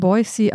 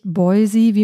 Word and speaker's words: Boise: Ich habe mich erkundigt bei Menschen, die in Boise, Idaho geboren sind. Es wird also mit scharfem S ausgesprochen, nicht Boise, wie manche vielleicht vermuten würden.